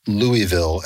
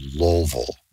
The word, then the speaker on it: Louisville: For example, Louisville, as I pronounce it, is called Louisville. – Louisville.